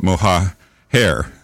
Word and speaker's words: Mohajer: Well, if you're of the Spanish dialect, you'd probably call it Mohajer. – And many people have. – Oh, so it's Mo. Well, I kind of thought it was Mohajer. – Yeah, would be the correct pronunciation.